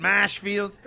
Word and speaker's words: Marshfield: Now, is that how you say it, Mashfield? – That's how we say it.